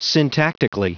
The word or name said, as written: syntactically